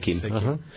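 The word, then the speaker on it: Seichim: You mentioned to us that not only do you work with Reiki, but also with Seikim. – That's right. – Am I pronouncing that correctly? – Yeah, okay. Seikim.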